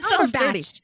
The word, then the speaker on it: Cumberbatch: You said Cumberpatch instead of Cumberbatch. And I started thinking Cumberpatch is. – That's Cumberbatch.